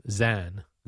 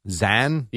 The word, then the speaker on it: Xan: How do you pronounce his name? X A N. – X A N. Zan. – Zan? – Yeah. – Why not just spell it Z-A-N? – Zan, because that's the way he spells it, Jerry.